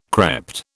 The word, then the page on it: crept: British English